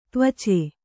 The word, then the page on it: ತ್ವಚ: tvaca